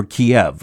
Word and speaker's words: Kyiv: The rise of Moscow, in one sense, came at the expense of Kyiv, or Kiev, as Americans often pronounce it.